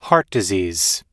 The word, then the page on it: heart disease: American English